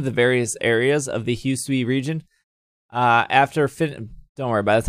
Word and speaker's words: Hisui: Many years ago, the land was called the Hisui.